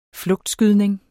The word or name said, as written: flugtskydning